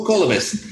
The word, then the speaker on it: Kalibas: Yeah, did I pronounce that correctly, Kalibas? – Kalibas, yeah. – Oh, Kalibas. Yeah, I had the wrong emphasis on the wrong syllable.